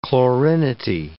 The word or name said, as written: chlorinity